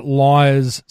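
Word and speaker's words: Lyre's: We're chatting with Mark Livings, who is the founder, the creator, the mad scientist, I don't know what we call him, behind Liar's Spirit, Liar's range of non-alcoholic spirits. It's spelled L-Y-R-E apostrophe S.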